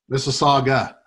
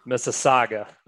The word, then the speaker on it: Mississauga: Mississauga. – Mississauga.